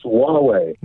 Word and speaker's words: Huawei: I think it's Huawei. – Huawei is close enough. I can't pronounce it any better than that.